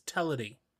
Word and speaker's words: Teledu: Oh, it is Teledu in Welsh as well? – The pronunciation is Teledu. – Oh, okay. – It is a slightly different pronunciation.